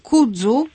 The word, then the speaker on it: kudzu: Pisze się K-U-D-Z-U. – K-U-D-Z-U. – D jak Danuta. – Tak. – Takie polskie dzy. Polskie dzy, kudzu.